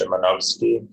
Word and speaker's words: Szymanowski: Yeah, Shimanowski. – Shimanowski, okay. – What? I told them it was Shimanowski. – Well, in Polish, the correct pronunciation is Szymanowski, but it was close.